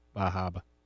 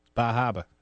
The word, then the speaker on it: Bar Harbor: Bahaba. – South of Bangor. – Banga. You got to pronounce these places right. Come on. Bahaba.